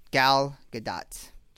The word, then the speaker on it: Gal Gadot: It's Gal Gadot.